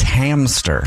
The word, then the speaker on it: hamster: It's hamster. – Yeah. – Like a ham. And stir. I've been mispronouncing that my whole life. Hamster. There's no P in hamster.